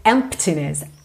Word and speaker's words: emptiness: A gente não pronuncia a letra P. Ok? Então não se preocupe em dizer emptiness, emptiness.